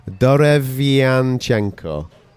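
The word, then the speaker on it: Derevyanchenko: Derevin Jinkango. – Sounds good. – Yeah. Sergei, I'm going to call him. – Mm-hmm. – I always get it. I can't do the pronunciation. Derevianchenko. – Nice.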